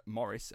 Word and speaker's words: Maurice: He is the eldest of two children to Maurice and Morris, actually, I should say. It's Morris, but it's spelt like it's Maurice, but the Australians say...